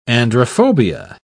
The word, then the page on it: androphobia: British English